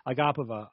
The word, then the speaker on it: Agapova: That's how you say it, Dean Thomas. Agapova.